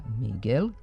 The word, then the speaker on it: Miguel: Ali s izvorno španskim imenom Miguel, koje se bez izuzetka na srpskom izgovara Miguel, i kad je portugalsko, godinama imamo teškoće. Model prema kojem bi se izgovor ovog imena mogao zapamtiti je ime slavnog španskog pisca Don Quixota, Miguel de Cervantes, potom ime bivšeg čuvenog španskog bicikliste Miguel Indurain, pa Miguel de Unamuno, kako se zvao španski pisac i filozof.